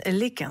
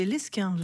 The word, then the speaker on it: Lesquin: L'US Léquin, c'est comme ça que l'on prononce voilà, le nom de cette équipe. Alors, moi, je pensais que c'était Lesquin.